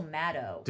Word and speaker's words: Maddow: But it's actually Rachel Maddow. – Did she talk about that on Watch What Happens Live? – As in, like, she's like, no, it rhymes with shadow, not shadow, which is interesting.